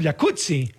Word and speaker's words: Jacuzzi: Entonces deberíamos pronunciarlo jacuzzi. – Exacto. – Como pizza. Como Rampazzo, sí. Como Claudia Rampazzo.